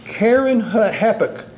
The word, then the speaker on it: Kerenhappuch: Kerenhappuch. That's how you that's how you pronounce her name.